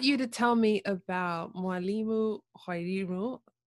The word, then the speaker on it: Mwalimu: It's Mwalimu. Mwalimu, which means teacher, and then my name is Wairimu.